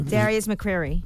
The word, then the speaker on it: Darius McCrary: I think that's his name. – Darius McCrary. – Okay, I said it right.